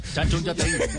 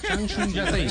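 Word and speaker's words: Changchun Yatai: ante el eh, Shang Shun Yatei. Eh, comienza el 11 de... – Espere, así se pronuncia. – Chanchun Yatei. Chanchun Yatei.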